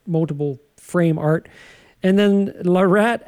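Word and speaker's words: La Ratte: Anyway, that was Ain't No Fun by Larate, L-A, and then R-A-T-T-E. That is pronounced Larate. It's French, but they are not French.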